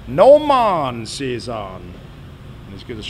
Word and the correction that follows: Gnomon: Oh, Gnomon. G-N-O-M-O-N. – The G is Gnomon. Silent. – The what? – The G is Gnomon. Silent. – The G is silent. – Indeed. – As in shit? – Yes. – Okay. – Gnomon. – Oh, no, it's not. – Like right. – A gnome. Garden gnome.